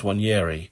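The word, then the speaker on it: Nwaneri: Okay, Ethan, I think it's pronounced Wanyeri, as if the N doesn't exist.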